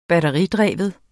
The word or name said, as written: batteridreven